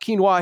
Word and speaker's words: quinoa: I actually do have a question about what even is quinoa, and am I saying it right? – Yes, you are.